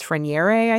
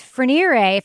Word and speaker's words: Freniere: F-R-E-N-I-E-R-E. Freniere. – Okay. So in New England, you just pronounce that Frenier. I don't know if that's how it's pronounced, but we take every French name and make it sound as dumb as possible.